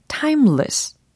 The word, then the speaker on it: timeless: 이렇게 발음 연습해 보시면 좋겠고, timeless, t-i-m-e-l-e-s-s. timeless가 아니고, timeless, timeless, timeless. 이렇게, 어, 두 번째 모음은 약화를 시켜주셔야 되겠습니다. timeless, timeless.